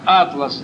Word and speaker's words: Атлас: Тогда густеет ночь, как хаос на водах, беспамятство, как атлас, давит душу. Лишь музы девственную душу в пророческих тревожат боги сна». Атлас – это здесь имеется в виду не географическая карта, это второе греческое произношение имени Атлант.